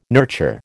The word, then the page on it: nurture: American English